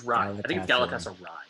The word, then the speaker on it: Galatasaray: I think it's Galatasaray. – Yeah. – You're right. I think you're right. Yeah. – You can ask Justin Paul.